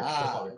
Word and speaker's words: Vren: The 55 is silent, but you just call me Vren. Okay. – It's just pronounced Vren then.